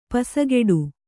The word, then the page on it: ಪಸಗೆಡು: pasageḍu